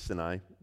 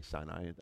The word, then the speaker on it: Sinai: But they lived in the desert of Sin, and so the mountain that was in that desert was Sinai, and so it's pronounced officially Sinai, but it's okay if you say Sinai. I'm not going to get too offended by it.